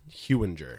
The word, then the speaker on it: Hewinger: I am Thomas Hewinger. – Hewinger. – I often get Hoinger. – Ooh. – Or Hunger. – Hunger a lot. Or I also get Hewinger. – Hewinger. – Soft G. Soft G. – It's a soft G.